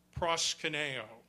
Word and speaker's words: proskuneo: Proskuneo is how it would be pronounced, the English pronunciation of the Greek word, proskuneo.